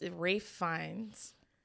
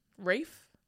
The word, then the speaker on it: Ralph Fiennes: So this is from someone named Alexandria, and I just learned how to say this guy's name. It's Rafe Fiennes. – Who? Rafe? – Rafe. – Raff.